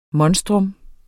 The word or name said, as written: MONSTRUMMETS